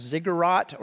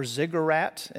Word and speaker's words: ziggurat: But there's something like a staircase or a ladder, or it may even be the image of, I've heard it pronounced ziggurat or ziggurat,